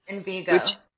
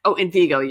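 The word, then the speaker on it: Envigo: Because the reason it did pop in my head, I think, is because I talked to them about Inviro. Was that how you pronounce it? – Invigo. – Invigo. Yeah. Sorry.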